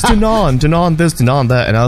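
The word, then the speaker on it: Dannon: Did you know that Dan in yogurt, it's pronounced Danon? – Yes. – I was watching a news... – You did? – Because it's French.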